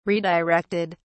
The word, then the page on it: redirected: British English